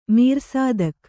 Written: ಮೀರ್‍ಸಾದಕ

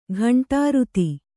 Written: ಘಂಟಾರುತಿ

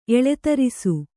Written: ಎಳೆತರಿಸು

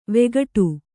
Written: ವಿಕಟ

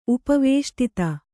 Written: ಉಪವೇಷ್ಟಿತ

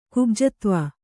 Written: ಕುಬ್ಜತ್ವ

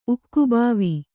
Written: ಉಕ್ಕುಬಾವಿ

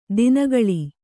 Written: ದಿನಗಳಿ

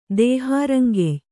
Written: ದೇಹಾರಂಗೆಯ್

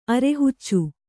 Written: ಅರಹುಚ್ಚಿ

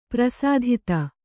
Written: ಪರಿಸ್ಥಿತಿ

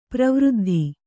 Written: ಪರಿವೃದ್ಧಿ

